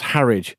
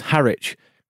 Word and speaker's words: Harwich: Look, I do actually love the show, but fucking hell, it's pronounced Harridge, or at worst, Harwich.